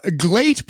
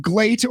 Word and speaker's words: Gleat: So Glate or Gleat Pro Wrestling, G-L-E-A-T. I think it's Glate pronounced. This is like, it's kind of supposed to be the new shoot style promotion, UWFI style.